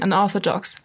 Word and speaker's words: Unorthodox: Ja, und das ähm, bringt mich dazu, zu meinem Serientipp der Woche zu kommen, und zwar ähm, unorthodox. Oder, nee, warte mal, ich glaube, ich glaube, es wird Englisch ausgesprochen, unorthodox.